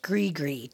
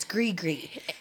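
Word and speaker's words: Gris-Gris: It's Good Witch Gree-Gree. G-R-I-S dash, or space, G-R-I-S. It's Gree-Gree.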